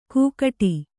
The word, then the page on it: ಕೂಗಟಿ: kūgaṭi